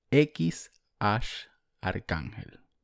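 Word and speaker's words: X Æ A-12: Según Elon Musk, en el, mm, en el podcast de Joe Rogan se dice X Arcángel. Repito, X Arcángel. Así se pronuncia según, según Elon Musk.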